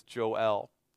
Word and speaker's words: Joel: His name is Joel, which is more appropriately pronounced if you have any experience with Latin American culture as Joel.